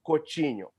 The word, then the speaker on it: Coutinho: So I got destroyed for even daring to say Coutinho. You know, pronouncing his name correctly.